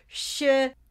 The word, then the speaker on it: Shiseido: I'm trying to like make sure I say it properly. Shiseido. – Yep. – See, I thought I had been saying it that way, but apparently I've been saying Shiseido.